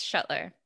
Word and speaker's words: Schuttler: Yes, Schuttler.